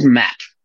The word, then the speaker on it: MAAP: They're called MAP. It's spelled M-A-A-P.